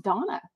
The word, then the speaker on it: Dana: Actually, I think her name is pronounced Donna, like Daily Dose of Donna, D-A-N-A.